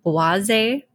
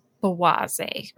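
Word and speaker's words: Błażej: But interesting side note, when you guys read it, it looks like Blaze J. – Yeah. – Mm-hmm. It's pronounced Boise, and it means stammering or stuttering.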